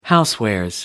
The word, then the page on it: housewares: American English